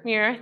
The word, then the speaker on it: Muir: I can't say his name very good. Muir, thank you.